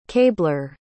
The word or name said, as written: cabler